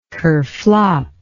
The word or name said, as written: kerflop